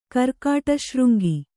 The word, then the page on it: ಕರ್ಕಟಶೃಂಗಿ: karkaṭa śřŋgi